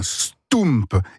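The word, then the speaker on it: stoemp: Ça s'appelle un stoump. Et ça s'écrit S-T-O-E, parce que O-E en belge, ça fait où ? – M-P.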